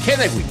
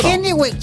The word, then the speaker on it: Kennewick: Así lo dije bien ahora sí. Kennewick, así está bien dicho, ¿no? – Kennewick, Chiquilín.